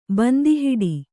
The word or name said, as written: ಬಂದಿಹಿಡಿ